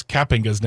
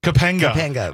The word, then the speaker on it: Kapinga: Kapinga's name incorrectly. Because we've been saying Kapinga and which is not it. – No one has corrected us. – Yeah, that's not it. It's Kapinga. – Kapinga.